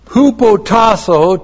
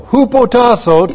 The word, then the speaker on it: Hupotasso: Hupotasso to your husbands. H-U-P-O-T-A-S-S long O. Hupotasso to your husbands.